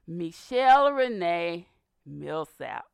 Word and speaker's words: Micshell Milsap: Now, my guest is Dr. Micshell Milsap.